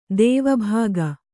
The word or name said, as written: ದೇವಭೋಗ